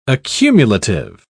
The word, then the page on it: accumulative: British English